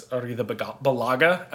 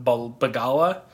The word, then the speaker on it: Arrizabalaga: I will say we said his name wrong. It's Arrizabalaga and not Bagala at the end.